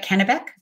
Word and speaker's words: Kennebec: Kennebec? – Yes.